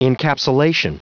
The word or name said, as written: encapsulation